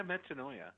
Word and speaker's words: metanoia: It rhymes with paranoia. – Yeah, I have a new word now. – I had a metanoia a couple of years ago,